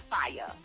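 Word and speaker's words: Fiya: That's F-I-Y-A, and that's Chick. And my name is spelled, and it's said Fire. Y'all be calling me Fia and all this Fia, Fire, Flyer. There's no L whatsoever in it.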